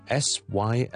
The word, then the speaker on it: Sylvia: 呢個名係 Sylvia， Sylvia， Sylvia， Sylvia， Sylvia。 There are two important things to pay attention to here. Number one, after the first syllable，你要有個 L 音，Syl 嗰個 L 咧係要讀出嚟嘅。你當自己好似讀 silly 呢個字，silla silly silly，但 instead of e 埋出嚟咧，照读个 L M，但系唔译出嚟。